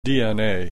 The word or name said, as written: DNA